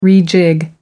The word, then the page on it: rejig: American English